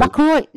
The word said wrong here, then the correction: McIlroy: How do you pronounce that? Is that McElroy? McElroy. – Only the biggest golfer in the world. – So is that how you pronounce him? McElroy. No. How'd you say it? – Rory McIlroy. – McIlroy.